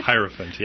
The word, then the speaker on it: Hierophant: Hierophant, yeah.